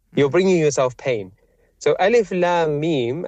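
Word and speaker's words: Alif Lam Mim (الم): It's a word that is spelt differently to how it's read, which is Alif Lam Mim.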